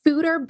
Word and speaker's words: foeder: I keep seeing fooder beers, lagers, I think, in Summit County breweries. What are there? So a fooder, which is spelled F-O-E-D-E-R, but pronounced food-er, basically is just like an oak barrel, like a wood barrel used to age a beer in, but it's a lot bigger.